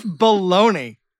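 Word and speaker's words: Bologna: Yeah, Joseph Baloney. Uh-huh. I made sure I was pronouncing it right.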